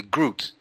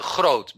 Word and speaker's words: Groot: And so a double O in English is an O, and you see the name of the Dutch ambassador in Paris, and for you it is Pieter de Groot. When in Dutch it is Pieter de Groot, because we have no G, we have only the G in Dutch, double O is a long O.